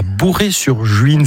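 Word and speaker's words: Bouray-sur-Juine: Henri Guibé, aujourd'hui vous vivez à Bourré-sur-Juine. C'est comme ça qu'on prononce l'endroit où vous vivez.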